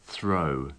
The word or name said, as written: throw